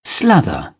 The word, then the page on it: slather: British English